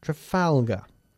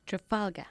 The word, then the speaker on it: Trafalgar: And by the way, we pronounce Trafalgar, Trafalgar. – Trafalgar.